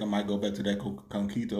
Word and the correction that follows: coquito: or whatever it's called. Coquito. Yeah, that. Say it right now.